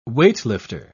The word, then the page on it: weightlifter: British English